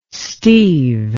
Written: Steve

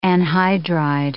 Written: anhydride

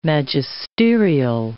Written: magisterial

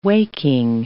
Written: waking

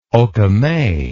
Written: okoume

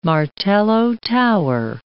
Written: martello tower

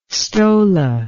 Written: stola

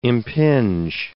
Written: impinge

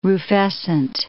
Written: rufescent

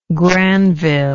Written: Granville

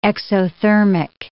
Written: exothermic

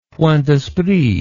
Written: point d'esprit

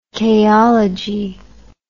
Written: chaology

